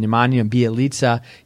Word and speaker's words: Nemanja Bjelica: Minnesota continues to start the appropriate at five, and off the bench, they're even playing Nemanja Bjelica.